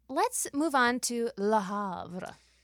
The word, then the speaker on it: Le Havre: Le Havre is a. – Oh no! I there's no. You don't pronounce the R.